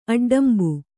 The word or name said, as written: ಅಡ್ಡಂಬು